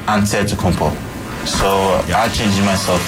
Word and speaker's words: Antetokounmpo: Whatever you gotta just say, you know, it's Giannis Antetokounmpo. – Antetokounmpo. – Antetokounmpo. – Boom. – Ante. So it's like an ant. Ante. – Antetokounmpo. – Tokounmpo.